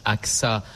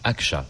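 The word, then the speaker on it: Ağca: Et qui raconte la vie d'Ali Argai. – Alors, ça se prononce Aksa en français, mais en turc, Aksa, puisqu'il était turc.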